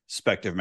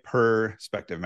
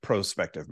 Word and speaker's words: prospective: I believe it's like pro, it's spelled out like P-R-O, prospective memory. So it sounds like perspective memory, but it's like prospective memory.